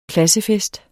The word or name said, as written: KLASSEFESTEN